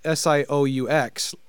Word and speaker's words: Sioux: We just 6 months ago had our third SuperCon where we but it's spelled SIOUX like Sioux Falls is where we're at.